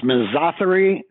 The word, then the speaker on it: misotheria: Yes, I think it should be pronounced misotheria, sort of rhyming with misogyny.